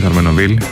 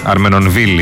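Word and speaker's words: Armenonville: Προφέρεται τον Αρμενοβίλ. Αρμενοβίλ είναι.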